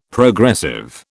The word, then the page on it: Progressive: British English